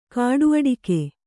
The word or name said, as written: ಕಾಡುಅಡಿಕೆ